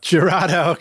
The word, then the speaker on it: Jurado: Producing and engineering albums for Damien Jurado. – Jurado.